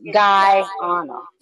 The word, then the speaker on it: Guyana: She's telling us how to pronounce it, or telling me how to pronounce it. It's Guyana.